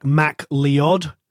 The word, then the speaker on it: MacLeod: So if the Americans are listening, it's like Mac Leod.